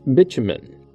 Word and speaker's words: bitumen: But bitumen is found in three separate instances in Genesis and Exodus, and, as you've noticed, I pronounce it one way, but it also can be pronounced bitumen, the British pronunciation. Neither is wrong, they are just different.